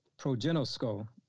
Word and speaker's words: proginosko: It, it is spelled, uh, well, it's pronounced progen no skull, proginosko, and that's spelled P R O G I N O S K O, Proginosko.